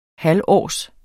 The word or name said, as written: halvårs